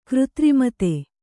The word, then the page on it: ಕೃತ್ರಿಮತೆ: křtrimate